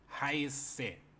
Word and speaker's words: heiße: Cuando ven esa palabra, imagínense un doble S y ya saben pronunciarlo. Ich heiße.